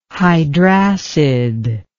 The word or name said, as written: hydracid